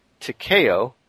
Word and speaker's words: Takeo: Small correction, the nickname is read like Takeo. He still puts T-A-K-E-O.